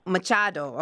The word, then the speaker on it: Machado: Machado, okay.